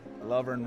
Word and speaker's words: Lovern: Like I said at this conference, you want to remind everybody that Lovern rhymes with govern.